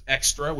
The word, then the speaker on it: Xtra: There's Extra, which is spelled X-T-R-A.